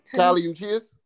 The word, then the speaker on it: Kali Uchis: Kali Uchis.